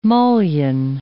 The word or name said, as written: mullion